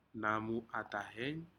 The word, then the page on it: namu atahen: Aneityum